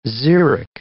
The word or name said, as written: xeric